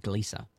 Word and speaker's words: Gliese: So that's how you say it, Gliese. – Gliese, yeah.